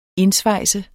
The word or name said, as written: indsvejse